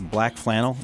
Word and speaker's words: BLK FLANL: Is this Black Flannel? Is that how you say it? – It is. They spell it curiously. – B-L-K-F-L-A-N-L.